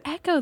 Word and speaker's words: Ecco: I thought that was pronounced Echo the Dolphin. – Yeah. Echo, sorry. Too. My apologies. – You're right. I don't know. It's spelled like E-C-C-O or something, right? – No, you're right.